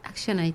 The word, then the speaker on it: ActionAid: Saludamos a técnica de proyectos de ActionAid. ¿Es así como se pronuncia? – ActionAid, sí.